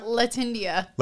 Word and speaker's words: Latindia: How do you say it? Latindia? – Latindia.